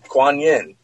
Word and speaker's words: Kuan Yin: Kuan Yin. And I said her name right, I think, right? – Yep.